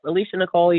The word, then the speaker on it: Alesha Nichole: Yep, Alesha Nichole, you got it right.